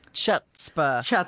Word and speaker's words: Chutzpah: Is one of those CH words that if you are not born to it, you have a lot of trouble because it's been pronounced, what is it? Chutzpah.